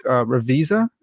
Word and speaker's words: Ravizza: So is it Mark Ravisa? – Yes. That's the way I've heard it pronounced.